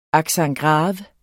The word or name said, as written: accent